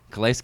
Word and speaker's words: Calais: Calais? – Yeah. Calais Campbell.